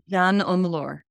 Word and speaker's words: Jeanne Omlor: Jeanne Omlor.